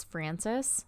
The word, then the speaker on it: Francis: My family name, my middle name is Francis, spelled with an IS, which is the boy way, if you were wondering.